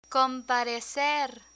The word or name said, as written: comparecer